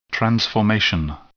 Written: transformation

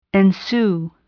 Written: ensue

